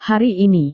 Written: hari ini

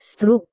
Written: struk

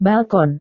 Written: balkon

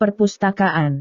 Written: perpustakaan

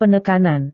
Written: penekanan

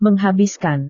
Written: menghabiskan

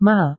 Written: mal

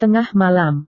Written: tengah malam

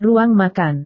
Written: ruang makan

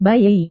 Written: bayi

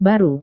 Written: baru

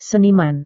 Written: seniman

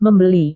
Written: membeli

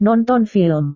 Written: nonton film

